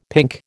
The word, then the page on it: pink: American English